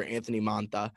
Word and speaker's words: Anthony Mantha: A 2021 first-rounder, a 2022 first-rounder for Anthony Mantha. I don't know if I pronounced that correctly, but I feel like I really just did well pronouncing things right there. – That was a good pronunciation.